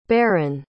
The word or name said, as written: baran